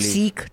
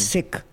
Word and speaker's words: Sikh: It's not Sikh. That's how the British say it. It's Sikh.